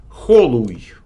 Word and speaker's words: Холуй: То есть, это, видимо, ударение должно быть на О, а не на У. Холуй.